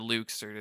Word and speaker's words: Luxord: Luxord is the correct pronunciation.